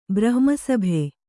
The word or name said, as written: ಬ್ರಹ್ಮಸಭೆ